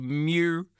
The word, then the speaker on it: MEU: They're called Mew, which is the surprising way to pronounce the name that is spelled MW:MEU. Simply just Mew.